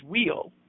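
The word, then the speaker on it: weal: So the person who did the recordings originally for the intros and outros and the commercials for my show was actually pronouncing it in actually what is the conventional pronunciation, which is wheel.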